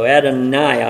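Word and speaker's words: Adonijah: And here is his name, Adoniah. Again, the J is not pronounced, kind of like we pronounce it. So Adoniah, I think, is the correct pronunciation.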